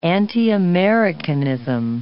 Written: anti-Americanism

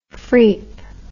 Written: freep

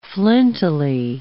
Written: flintily

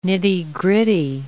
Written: nitty-gritty

